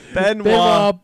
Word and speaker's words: Benoit: Benoit. – Thank you. Benoit Blanc. – Damn it! All the hell. – Welcome to McDonald's. Can I get a McBlanc? – Benoit Blanc.